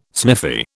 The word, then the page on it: sniffy: British English